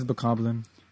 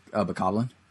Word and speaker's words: Bokoblin: In fact, no, but when you it is Bokoblin. – Yeah, Bokoblin.